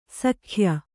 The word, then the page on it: ಶಾಕ್ಯ: Kannada